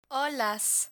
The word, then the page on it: olas: spanish